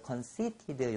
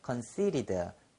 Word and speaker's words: conceited: conceited 자 또는 부드럽게 발음하면 conceited 자 요것은 그 t 앞에 모음이 오고 t 뒤에 모음 즉 모음과 모음 사이에 t가 오면은 이것이좀 부드러운 알 발음 비슷한 현상을 일으킵니다. 그래서 water 안 그러고 water 그러잖아요. 그래서 conceited 요것도 conceited 자, 이렇게 부드럽게 하는 것은 사실 미국식 발음이고 영국에서는 water 이렇게 하지 않고 water 이렇게 t 발음을 정확히 해주는 그러한 식으로 합니다.